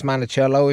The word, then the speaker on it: Monticello: We drove down to Monticello, Indiana, pronounced Monticello if you're a local.